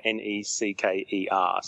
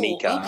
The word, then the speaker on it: Necker: He actually spelled it NECA, NECKER, so that's how I knew it wasn't NECA.